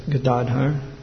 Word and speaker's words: Gadadhar: In the Panchatattva, Radharani is present as Gadadhar, not Gadadhara. Many devotees say Gadadhara, but the accent is on the second syllable, Gadadhar.